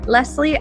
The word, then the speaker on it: Lesley: If we aren't yet connected on Instagram, come follow me at Lesley and Co XO.